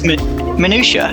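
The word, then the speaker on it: minutiae: The little minuate of like everyday life. – Minuate? I thought it was minutia. Like, it's spelt really weirdly, right? But it's minutia, I'm sure.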